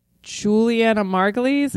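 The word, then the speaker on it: Julianna Margulies: Okay, so Juliana Margulies, am I saying her name right? – Uh-huh.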